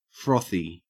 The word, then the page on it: frothy: Australian English